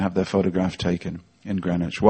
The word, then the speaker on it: Greenwich: It's spelt Greenwich.